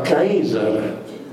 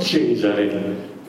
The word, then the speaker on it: Caesar: Cesare, che si pronunciava Caesar, non Cesare, Caesar. Donde Kaiser? Perché la C, col passare degli anni, si è ammorbidita in K.